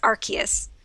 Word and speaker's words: Arceus: How I learned that I've been saying Arceus wrong. I always say Arceus, so now I know that I was saying that wrong for the past. – Oh, it is Arceus? – No, it's.